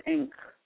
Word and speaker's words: Ankh: By the way, you also will find out that Ankh is really supposed to pronounce Ankh. If you listen to our brilliant scholar, Dr. Ben, you will find that he also pronounces it Ankh. It is Ankh. There is something very essential about that long A sound.